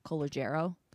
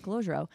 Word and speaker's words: Calogero: And I always thought it was Calogero. Calogero. – Mm-hmm. – Turns out not pronounced Calogero. It's Calogero.